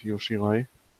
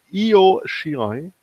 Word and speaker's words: Io Shirai: Zusammen mit Yoshirai. Oder äh, wie sie, wie die es bei NXT aussprechen. Io Shirai. Ja, richtig.